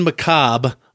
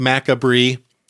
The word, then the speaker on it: macabre: Then you want to hit up Mountain Macabre on Facebook. Looks like macabre, if you've only read the word. Pronounced macabre.